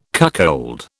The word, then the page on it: cuckold: British English